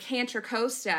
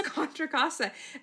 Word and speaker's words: Contra Costa: When I was reading this audiobook, it was so obnoxious because I used to live in Contra Costa County, and he called it the weirdest thing, like Cantra Costa. And I was like, what the fuck? It's Contra Costa.